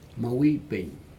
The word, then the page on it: mau ipig: Aneityum